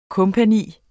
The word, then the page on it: co.: [ ˈkɔmpaˌniˀ ]; [ ˈkoˀ ]